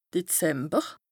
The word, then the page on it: Dezember: Bas-Rhin; Strasbourg